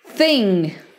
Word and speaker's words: Thing: Thing. Thing. Thing. Esse G é um G mudo. Você praticamente não vai pronunciar, né?